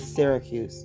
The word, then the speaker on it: Syracuse: We stopped at the city of Sarkus. We stayed there, uh, and did I say that right? It's Syracuse.